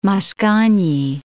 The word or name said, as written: Mascagni